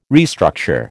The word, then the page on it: restructure: American English